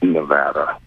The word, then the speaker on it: Nevada: And if you go to the Nevada state website, they even have the punctuation over one of the A's to indicate it's pronounced Nevada. – All right. Thank you. – They're very Nevada.